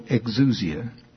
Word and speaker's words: exousia: It is pronounced, I hope, exousia, E-X-O-U-S-I-A.